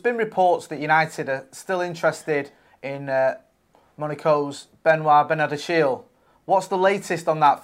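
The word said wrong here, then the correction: Benoît Badiashile: The name in French is Benoit Badiachil.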